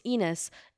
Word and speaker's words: Enos: It's Enos. It rhymes with penis, so you can call me penis or Enos, whichever one.